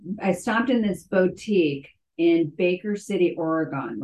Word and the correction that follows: Oregon: Oregon, excuse me. They call it Oregon, even though it's spelled Oregon, but whatever.